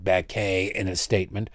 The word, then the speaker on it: Baquet: The executive editor of the New York Times released a statement, Dean Baquet. You know he's a serious person because he pronounces his name that is spelled Beckett with a French ending to it.